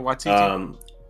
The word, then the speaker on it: Waititi: But was it Watiti? What was his name? The director? Yeah, Watiti. – Taika Waititi.